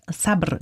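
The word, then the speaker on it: Sabr (صبر): Sabr, Geduld. – Genau, auf jeden Fall. – Das richtig ausgesprochen sogar.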